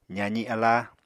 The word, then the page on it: zaazi aláa: apatani